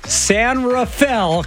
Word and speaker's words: San Rafael: Denise is listening and donating from San Rafael, California.